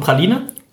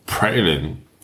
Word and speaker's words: praline: Was ist die englische Aussprache von Praline? – Pralin. – Sicher? – Pralin. – Pralin.